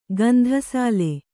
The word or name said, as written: ಗಂಧಸಾಲಿ